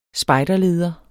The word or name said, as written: spejderleder